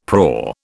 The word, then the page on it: prau: British English